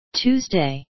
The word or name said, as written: tuesday